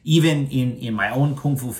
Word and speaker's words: Kung Fu: Yeah, that one actually is Kung Fu. Gong, not Kung. Gong is start from G. Kung Fu, first tone. Kung Fu. – Yes, that's also a very good point that it should be pronounced like with a G sound, not with a K sound.